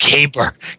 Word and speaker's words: caber: No, it's pronounced caber. It's a caber, laddie.